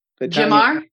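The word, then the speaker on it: Gemar: There's a Gimar, however you pronounce it. – Gimar. Yeah.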